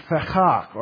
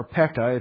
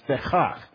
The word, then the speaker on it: Pekah: and Fechach, or Pekah, if you read it in your English, Tikah is how the Bible, the audio Bible guy will pronounce it, Pekah, it's Fechach. Can you say Fechach?